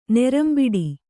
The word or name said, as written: ನೆರಂಬಿಡಿ